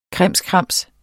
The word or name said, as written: krimskrams